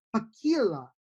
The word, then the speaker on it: Pakkila: And that is left winger Eetu Pakila. And it's spelled, his first name is spelled E-E-T-U, and his last name is spelled P-A-K-K-I-L-A.